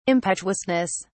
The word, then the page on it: impetuousness: British English